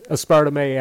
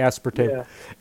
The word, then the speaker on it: aspartame: Well, people pronounce it both ways, aspartame, aspartame.